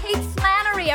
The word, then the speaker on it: Kate Flannery: We have Kate Flannery. Are we saying that correctly? – Yeah, I think you are.